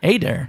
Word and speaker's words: Adair: It's a long A. It's Adair. – Adair.